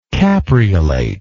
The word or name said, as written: capreolate